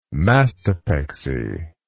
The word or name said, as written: mastopexy